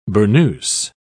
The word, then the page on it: burnous: British English